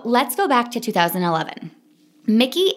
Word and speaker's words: Miki: So this woman's name was Mickey Agrawal, okay? – Ooh, cool name. – So, and it's spelled M-I-K-I. Like, it's a cool way, okay? – Mickey.